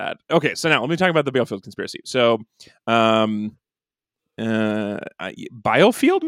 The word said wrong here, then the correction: Bielefeld: maybe, is actually how you say it? Yeah. Biofield. – Well, yeah. – I don't know how to read the pronunciation guide I gave myself. – Bielefeld.